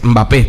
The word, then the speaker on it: Mbappé: pero lo cierto es que la correcta pronunciación de su apellido es Mbappé, tal cual, como Mbeyú.